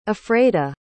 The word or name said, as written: afreda